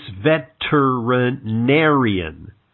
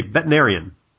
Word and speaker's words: veterinarian: However, I would like to point out to you the pronunciation of the word veterinarian, as I am one, and I notice you leave out a syllable of the word. It's veterinarian. And I got to admit, Gary nailed me because I kind of say veterinarian, like a lot of people do, right? – I say it like that, veterinarian.